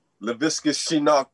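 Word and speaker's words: Laviska Shenault: I'm going to start with saying LaVisca Chenault Jr. That's how you say his name.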